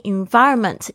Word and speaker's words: environment: Put yourself in an all English speaking environment. Environment 要注意一下，大家 v 的发音哦，这个是 v 的发音。 Environment 必须要感觉到你的上排牙齿轻轻拨到下排下嘴唇，然后发出这个 v 的声音。